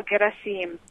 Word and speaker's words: Gerasim: The Gerasim, often we want to put the accent on the Gerasim. You know, in English, that's how we would do it. But I think it falls on the third syllable, Gerasim.